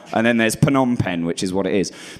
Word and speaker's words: Phnom Penh: There's three stages of thinking you know how to pronounce Phnom Penh. The first is that you say Phnom Penh, which is wrong.